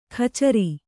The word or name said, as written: ಕಚರಿ